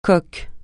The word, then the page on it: coke: francais